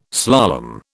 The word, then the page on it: slalom: British English